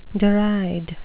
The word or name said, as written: deride